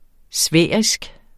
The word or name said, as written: sfærisk